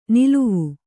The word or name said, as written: ನಲಾವು